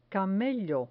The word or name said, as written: camello